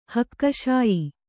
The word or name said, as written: ಹಕ್ಕುಶಾಯಿ